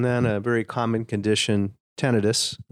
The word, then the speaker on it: tinnitus: The most common association for tinnitus is, and it is tinnitus, so thank you, for getting it right. And often patients will call it tinnitus, so tinnitus is the correct pronunciation for it.